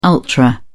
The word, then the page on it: ultra: British English